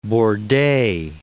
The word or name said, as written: Bordet